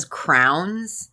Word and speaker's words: crayons: Oh, I have met people who call them crowns. – Crayons? – That's how they pronounce crayons. Wow. They'll go crowns.